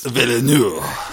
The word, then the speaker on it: Villeneuve: But I think that the new one, Denis Villeneuve... Mm-hmm. Is that how you pronounce it? – A little more French, please. – Villeneuve.